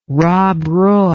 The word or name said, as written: Rob Roy